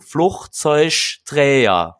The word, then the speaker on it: Flugzeugträger: Äh, das Wort Flugzeugträger wird auf drei verschiedene, also der, der gleiche Buchstabe wird an drei Stellen anders ausgesprochen. Flugzeugträger.